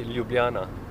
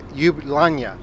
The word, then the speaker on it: Ljubljana: No, the right pronunciation would be Ljubljana. – Ljubljana. – Ljubljana. No, you're saying the last J is...